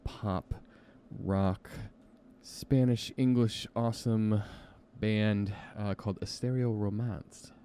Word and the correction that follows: Estereomance: Oh, am I pronouncing that right? Estereomance. That's what it is. Estereomance and they got a new song.